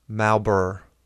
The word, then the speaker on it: Marlborough: You pronounce it like this. Malbur. – Oh. – M-A-L-B-R-R-R.